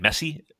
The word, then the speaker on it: Messi: And that's how you pronounce his name, Messi, like M-E.